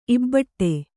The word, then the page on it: ಇಬಡಿ: kannada